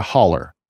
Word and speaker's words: hollow: And I grew up what's called a holler. Right. So the actual word is hollow, H-O-L-L-O-W. We pronounce it a holler